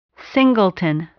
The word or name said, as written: singleton